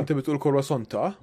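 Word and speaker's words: croissant: كرواسونت. – ايمن ايمن ايمن ما بتقول كرواسون؟ – لا، بتحب تلفظ التي؟ – ما بتحكي الار لما تلفظها، هي كواسون.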